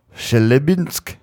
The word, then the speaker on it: Chelyabinsk: It's the Chelyabinsk meteor.